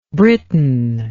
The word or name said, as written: Britton